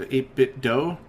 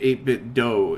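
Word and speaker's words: 8BitDo: So, pronouncing it 8-bit do is probably, you know, it is not probably, it's definitely the right way.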